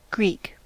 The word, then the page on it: Greek: American English; [griːk]